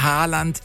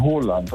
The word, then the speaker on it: Haaland: Erling Haaland ist falsch, ne? – Ja, das ist absolut falsch. Er wird ausgesprochen Haaland, also mit O. – Ja. – Haaland, und zwei A's werden als O ausgesprochen und ein A mit einem Kringel oben drauf ist eigentlich auch eine Aussprache, ein O.